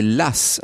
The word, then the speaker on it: Law: On a notamment cette catastrophe financière, ce banquier écossais qui s'appelle John Law. Alors on disait Lass à l'époque, hein, ça s'écrit L-A-W.